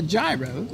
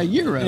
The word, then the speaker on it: gyro: He didn't call it a gyro. He called it a Euro, but he calls it soup.